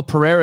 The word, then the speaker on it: Pereira: Maybe I said his name wrong. Maybe I think it's Alex Pereira. – Alex Pereira. Thank you.